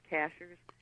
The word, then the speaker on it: Cashiers: The only thing that's difficult with the website for people is the spelling of Cashers, and that's how they pronounce it. But it looks like, you know, a cashier. – Yeah. – And it's CASHIERS.